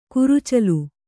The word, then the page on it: ಕುರುಚಲು: kurucalu